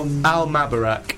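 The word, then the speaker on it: Al Mubarak: Al Mabarak. – That's how you pronounce it as well.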